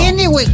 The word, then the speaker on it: Kennewick: Así lo dije bien ahora, sí. Kennewick, así está bien dicho, ¿no? – Kennewick, chiquilín.